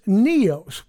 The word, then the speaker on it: neos: The word used for the new wine is neos. We might spell it in English, N-E-O-S.